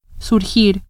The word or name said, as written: surgir